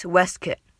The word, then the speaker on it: waistcoat: It's spelled, it's pronounced Westkit.